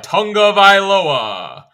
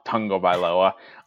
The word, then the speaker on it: Tagovailoa: It is tongue as in the appendage in your mouth, Tagovailoa.